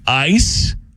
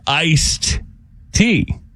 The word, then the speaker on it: iced tea: Ice, I-C-E-T, or is it I-C-E-D-T? How do you say it? Like, it's warm outside. You want to come over for some lemonade or... – Oh, it's... The correct way is with a D, but I only say iced tea.